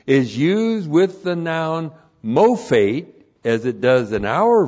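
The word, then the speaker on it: mophate (מופת): Looks like that in the Hebrew, M long O P H E T, mophate.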